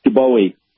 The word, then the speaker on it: Dobowey: Dubowie. – Spell that for me. – D-O-B-O-W-E-Y.